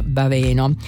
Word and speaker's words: Baveno: C'è poi il nostro amico che ci corregge la pronuncia di alcune lettere, allora ci manda questo: il nome della città Baveno si pronuncia con l'accento sulla seconda sibila Baveno, è una E aperta. Per ricordarlo facilmente si fa rima con treno, meno, ripieno.